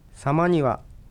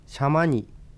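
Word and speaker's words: 様似: さまにはさまにと読むのが普通ですが、 5、 60 年前までは地図にシャマニと書かれたり発音されることが多く、現在もシャマニと発音するお年寄りもいます。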